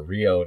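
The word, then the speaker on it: Ryo: Yo Ishikawa, or Rio now, as we're supposed to call him, because he keeps changing the pronunciation of his name.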